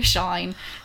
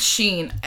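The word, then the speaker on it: Shein: if it's Sheen or Shine. Somebody tell me which it is. – I think it's Sheen. Every time I've seen it's Sheen, people in their TikToks and their 1 euro bikinis.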